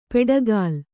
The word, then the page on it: ಪೆಡಗಾಲ್: peḍagāl